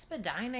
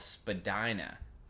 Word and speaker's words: Spadina: But as we moved here from the West Coast, that has more of a Latin influence on the way that we pronounce our words out west. We're like, Spadina. That's for sure Spadina. – And yeah, 100% we didn't think it was Spadina.